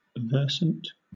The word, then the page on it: versant: British English